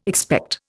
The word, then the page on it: expect: Indian